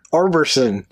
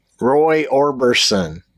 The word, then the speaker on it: Orbison: Roy Orbson. – Orbison. – Orbison. – I don't think we're saying it right. I think it's Orbison. Roy Orbison.